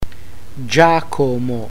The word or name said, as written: giacomo